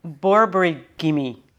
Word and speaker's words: borborygmi: Oh, say it again. I think you might have mispronounced it. – Okay, so borborygmi.